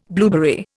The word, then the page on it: blueberry: Indian